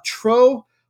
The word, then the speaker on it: Trou: It's called, I'm going to pronounce it, uh, Trou. Poisson, T R O U, I'm pretty sure the Poisson.